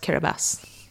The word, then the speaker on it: Kiribati: K-I-R-A-B-A-T-I. Yeah, it's eight letters. – How what? Kiribati. – That's how it's spelled. It's spelled Kiribati, yeah, but it's pronounced Kiribati.